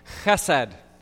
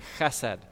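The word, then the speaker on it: chesed (חסד): And so repeat after me chesed. Yeah, you got to get a little bit of phlegm in your throat in order to, to say it right.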